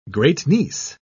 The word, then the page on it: great-niece: British English